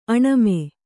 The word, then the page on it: ಆಣ್ಮ: āṇma